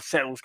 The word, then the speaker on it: Seuss: pues las historias del Dr. Seuss o el Dr. Zeus, como ustedes lo pronuncian, eh, el Grinch.